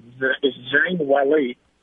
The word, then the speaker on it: Zhang Weili: All right, so the Chinese lady, you say her name backwards, is Zhang Weili.